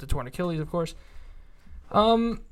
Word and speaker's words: Karlaftis: I have George Karlaftis. Is that how you say his name? – George Karlaftis, correct.